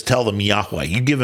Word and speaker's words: Yahweh: So those four letters, yod, hey, wa, hey, or vav, hey, is what spells out what we pronounce often as Yahweh or Yahweh.